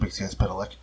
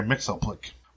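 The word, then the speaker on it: Mxyzptlk: I must compliment you on the proper pronunciation of Mixias Pidalic. I cringe whenever I hear Mixoplic."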